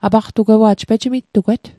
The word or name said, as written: apaqtugewa'j